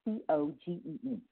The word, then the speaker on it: Muscogee: But Crawford cut a deal with prosecutors Tuesday, showing up to Massachusetts County Court in Georgia. I might be pronouncing that wrong, but it's Muscogee County Court in Georgia, to enter a plea to charges of child molestation and sodomy.